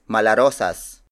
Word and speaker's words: malarosas: Another modern variation is malarosas.